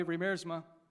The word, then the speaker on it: Riemersma: So, Riemersma or Riemersma. I don't know how they say it. Apparently, we're saying it the American way, Riemersma.